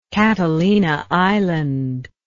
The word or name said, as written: catalina island